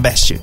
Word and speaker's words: Best: Tudo sim, Claquete na Best. O T é mudo, Best.